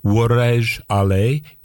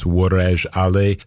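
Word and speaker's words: Woradj Alle: And it comes from her solo debut album called Worej Ale, Impressions of Ethiopia for Solo Violin and Electronics. It's quite a name there. Those first couple words, Worej Ale, that's spelled W-O-R-A-D-J, and then A-L-L-E, Worej Ale.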